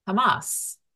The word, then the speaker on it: chamas: The word violence in Hebrew is chamas, which is spelled C-H-A-M-A-S. And in English, we would pronounce it Hamas.